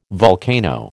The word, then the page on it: volcano: American English